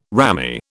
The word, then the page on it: rammy: British English